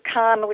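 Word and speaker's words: Cannes: You pronounce it ka, kind of like K-A-H, ka. – Ka? – Oh, okay. Ka, okay. – That's just how it's pronounced, versus Khan, which is the one in the south where the film festival is.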